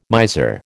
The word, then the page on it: miser: American English